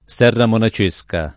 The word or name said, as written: Serramonacesca